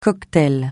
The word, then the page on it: cocktail: francais